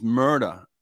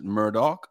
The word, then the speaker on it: Murdaugh: I don't know if you've seen it, but this guy's last name is Murder, but it's spelled like M-U-R-D-A-U-G-H. Okay. That's how his name is spelled like that, Murder. But they pronounce it Murdoch.